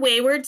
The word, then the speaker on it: Wheyward: So it's called Wayward Spirit, and it's spelled Wheyward, spirit, just like you would expect. And it's because it's a more sippable and sustainable specialty spirit actually made from whey.